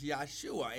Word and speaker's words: Yahshua: This is just what King James tried to translate from Hebrew into the King James English, but his name was actually Yahshua. It would have been pronounced Yahshua, amen.